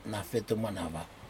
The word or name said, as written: nafetumenava